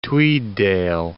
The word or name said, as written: Tweeddale